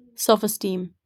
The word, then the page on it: self-esteem: /ˌsɛlf.əˈstiːm/